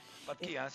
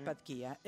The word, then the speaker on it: Patquía: Patquía, creo que se pronuncia, ¿no? – Patquía, sí. – Patquía.